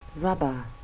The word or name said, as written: rubber